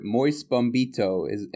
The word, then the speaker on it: Moise Bombito: Moise Bombito, am I pronouncing that correctly? – Perfectly, yep.